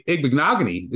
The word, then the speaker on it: Igbinoghene: And then you got your boy Igbinoghene is who you're relying on.